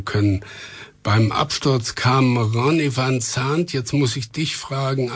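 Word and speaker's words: Van Zant: Van Zandt. Ich würde das Z stimmhaft, als stimmhaftes S aussprechen. – Okay. – Ronny van Zandt.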